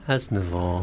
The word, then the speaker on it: Aznavour: Aznavour.